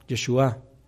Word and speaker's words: Yeshua: Yeshua, vamos a darle el acento en la última A, Yeshua,